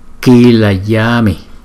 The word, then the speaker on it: kilayami: Và chữ A có dấu gạch ngang này chúng ta đọc dài hơn là chữ A trước này. Kila yami.